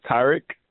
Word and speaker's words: Tarik: I mean, the actual pronunciation is Tyrek, like the A in apple, but I stopped really caring about pronunciation a couple years ago, so it's not too much of a deal to me.